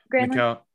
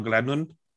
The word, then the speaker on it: Granlund: Granlund? Mikael Granlund.